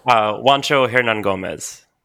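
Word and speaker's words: Juancho Hernangómez: Boy, I'm going to try to pronounce Bo's real name. Honcho Hernan Gomez? Might be right. – Do you want some help with that, Ken? – Yeah, do you know it? – Juancho Hernan Gomez.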